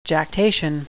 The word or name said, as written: jactation